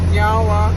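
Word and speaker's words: Niá:wen: So, in our language, we say nyawa, which means thank you. – Nyawa. – Nyawa. – I said it right? – Yeah, you did.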